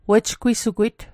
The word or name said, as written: wejgwisugwit